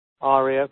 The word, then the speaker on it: Aria: If anyone else wants to re-pronounce that, she's written her pronunciation here. Aria, pronounced R-E, like Australia. So that should hopefully make sense.